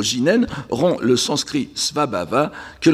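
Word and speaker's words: jinen (自然): Donc, prononcer au sens, au Japon, jinen dans un contexte bouddhique, parce que le japonais fait très clairement la différence entre le shizen nature actuelle et le shizen bouddhique, qui est alors prononcé jinen, je n'insiste pas là-dessus.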